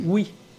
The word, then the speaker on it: Wy: Au nord de Paris, il y a une commune qui, dont le nom s'écrit WY. Eh bien, on dit oui.